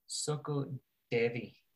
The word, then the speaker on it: SOCODEVI: Soko Devi.